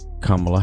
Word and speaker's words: Kamala: So, congratulations to the Democratic Party, President Joe Biden, and Vice President Kamala Harris. Now, let's make sure, people, we start pronouncing her name properly, okay? Kamala. Kamala. Kamala.